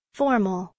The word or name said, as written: formal